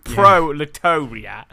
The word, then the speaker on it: proletariat: Like the it's got two stressed syllables. Proletariat.